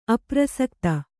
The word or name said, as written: ಅಪ್ರಸಕ್ತಿ